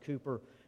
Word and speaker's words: Cowper: It's written by the hymn writer William Cowper, looks like Cowper, but pronounced Cooper, who was a brilliant man, but a man who could very much identify with Psalm 42:43.